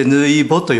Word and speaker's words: 抜き穂: それでぬいぼと読むんです。 – 抜き穂じゃなくてぬいぼって読むんですね。